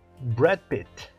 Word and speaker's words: Brad Pitt: Então a pronúncia correta Brad Pitt. Brad Pitt. E quando nós dizemos Brad Pitt, você viu o filme do Brad Pitt? É como se você estivesse dizendo Bradzinho Pittzinho. Ok? Então, pronúncia correta Brad Pitt e não Brad Pitt.